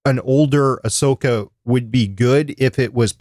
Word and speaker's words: Ahsoka: Is Ahsoka, or how do you pronounce it, Chris? – Ahsoka.